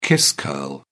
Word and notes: kiss curl: British English

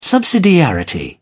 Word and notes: subsidiarity: British English

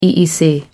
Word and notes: EEC: American English

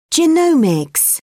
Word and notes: genomics: British English

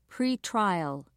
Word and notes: pretrial: British English

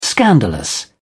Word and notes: scandalous: British English

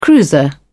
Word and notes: cruiser: British English

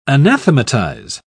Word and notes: anathematise: British English

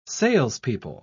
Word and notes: salespeople: British English

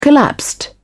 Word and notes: collapsed: British English

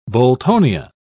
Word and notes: boltonia: British English

